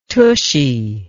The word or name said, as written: tushie